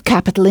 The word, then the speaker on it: capital: En insistant bien sur la première syllabe, « ka », « capital ». C'est comme si vous frappiez sur cette première syllabe, « capital ».